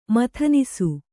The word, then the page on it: ಮಥನಿಸು: mathanisu